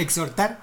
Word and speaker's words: exhortar: Exhortar, la A, la H, no se dice.